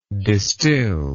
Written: distillable

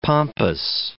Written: pompous

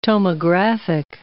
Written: tomographic

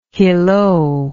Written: hilloa

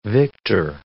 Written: victor

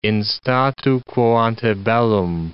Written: in statu quo ante bellum

